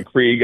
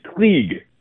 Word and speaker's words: Krieg: And people saw the leg that Lenny Craig had. Yeah. Krieg, I'm sorry, I was corrected by a German friend on how to pronounce his name. It's Krieg. Krieg.